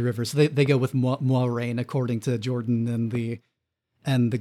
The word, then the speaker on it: Moiraine: Officially, it's sort of pronounced the French way, but not quite. It's like Moiraine.